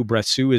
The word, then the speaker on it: Brassus: It's Letters du Brassou. Letters is spelled kind of the French way, L-E-T-T-R-E-S. Du Brassou. Brassou is spelled B-R-A-S-S-U-S. Brassou, the last S is kind of silent.